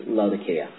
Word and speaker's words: Laodicea: See the Greek there? See the little K? Went from Greek K to a Latin hard C, which got translated into English. And for some reason... For some reason, it became soft. Anyway, the proper pronunciation is Laodicea.